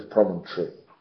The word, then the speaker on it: promontory: Pero el resto de nosotros, el resto de los Estados Unidos, Inglaterra, Irlanda, etc., es promontory. Y estaría bien que aprendieras a pronunciarlo así, porque así se pronuncia.